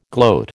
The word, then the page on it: gloat: American English